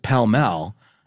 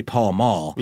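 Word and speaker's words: Pall Mall: But grandpas pronounce it pell-mell. And if you look at the pack, it's clearly Paul Mall. – Yeah. – And they're still around. But apparently, they're like, that's one of these examples of willful disagreement of an obvious pronunciation.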